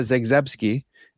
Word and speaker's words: Zagzebski: It's Dr. Linda Zagzebski, and she's awesome.